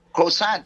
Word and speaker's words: croissant: Croissant ba yun? Tama ba yun? – Croissant.